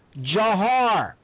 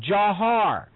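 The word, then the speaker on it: Dzhokhar: And they all said that he said to call him, and I quote, Jahar, which is an Arabic name, Jahar. I know how it's spelled. That's the Russian spelling. The man's name is Jahar.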